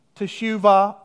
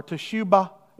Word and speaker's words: teshuvah: That's the Hebrew word, teshuvah, or teshubah is the original word. They use it. They turn that B into a V at times. Teshuvah is the most popular pronunciation of it now.